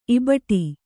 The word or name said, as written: ಇಬಡಿ